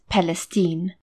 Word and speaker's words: Palestine: They felt that they were still under threat from George, and then relocated to Palestine, Texas, which the locals pronounce as Palestine.